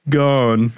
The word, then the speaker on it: Ghosn: Because Carlos Ghosn's name is pronounced gone, and it sounds a little like gone.